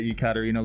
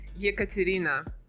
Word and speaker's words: Ekaterina: And our guest today, Ekaterina Lisina. – Hello. – How do you pronounce your name? – Ekaterina.